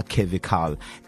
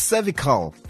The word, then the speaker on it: cervical: Yeah, iyenza ukuthi na ikhoze laphayana human papillomavirus yona ekhoza kakhulu inina ama cervical cancers. So it's cervical cancer, ladies and gentlemen, not cervical cancer.